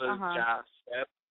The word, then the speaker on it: Yasser: Well, yeah, I mean, the name is Arabic, and I guess the Spanish way to pronounce it will be Yasser, as in, Yasser. – Like, the J instead of the Y is pronounced. So Yasser, like, yes, sir, you know.